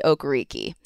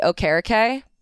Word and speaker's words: Okereke: Also something that I found out at camp, it is not pronounced Bobby Okereke. He is going with a more traditional Nigerian pronunciation. So it's Bobby Okereke.